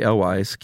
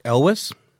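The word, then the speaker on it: Elwes: For some reason, Carrie Elwise keeps getting mentioned, and you say Carrie Elwise. How do you pronounce it? – Elwis. – You say Elwis? – Elwis, yeah.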